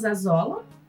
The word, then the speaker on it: Azola: Isso, Fabiano Atenas Azola.